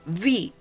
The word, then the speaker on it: wie: Ejemplo, vi, para preguntar, vi día, vi, aunque se escribe W-I-E, se dice vi. Eh, también para preguntar qué, vas, aunque se escribe eh, W-A-S, suena V, porque la letra W se pronuncia en alemán como V.